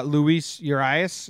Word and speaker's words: Luis Urias: The other one, now I have Luis Urias, is that how you say it on here? – Right.